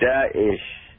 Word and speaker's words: Daesh: Dear listeners, this is how you say it. Daesh, Daesh. Did you get it? Daesh. It's two syllables. The first part is easy. Da, like the first part of Dawji.